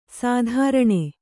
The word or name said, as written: ಸ್ತ್ರೈಣ